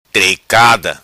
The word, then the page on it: Tricada: Macanese Patuá